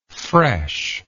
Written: freshness

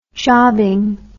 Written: jobbing

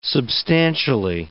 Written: substantially

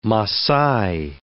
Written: Masai

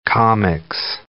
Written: comix

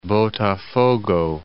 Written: Botafogo Bay

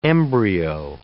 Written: embryo

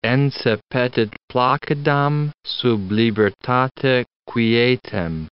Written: ense petit placidam sub libertate quietem

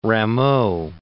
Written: Rameau

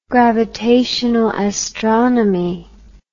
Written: gravitational astronomy

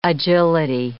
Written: agility

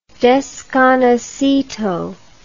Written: desconocido

